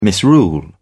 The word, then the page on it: misrule: British English